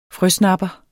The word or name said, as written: frøsnapper